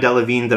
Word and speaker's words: Delevingne: Cara Delevingne, did I say that right? – Yes.